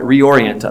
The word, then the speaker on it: reorient: That our worship would reorient us. Did I say that right? Reorient us. There we go. That's where the emphasis goes.